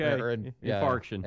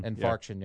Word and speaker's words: infarction: Myocardial infraction. – Okay. Infarction. – Infarction, yeah.